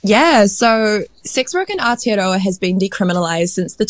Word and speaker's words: Aotearoa: I live in Aotearoa, New Zealand. – Aotearoa.